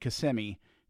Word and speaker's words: Kissimmee: So we're Disney World is in this place called Kissimmee. It's spelled like Kissimmee. Hey, kissing me here.